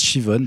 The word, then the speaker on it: Siobhan: Mais comment tu expliques Shivon? – Alors, Shivon, attention, c'est rien à voir, c'est S-I-O-B-H-A-N, en fait. Ça se prononce Shivon parce qu'elle est dans un coin très reculé d'Angleterre.